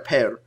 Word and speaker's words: Pere: So now, how do you say it? Pear Ubu. – Pear.